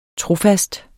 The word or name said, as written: trofast